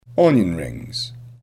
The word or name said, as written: onion rings